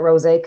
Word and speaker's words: Rosé: It's T-H-E-C-O-C-O-R-O-S-E, but we're pronouncing it Rosé because, you know, put the stank on it.